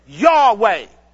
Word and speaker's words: Yahweh: That's how you pronounce his real name. Yahweh.